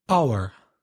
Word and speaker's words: hour: Our. So H-O-U-R, the initial H is silent.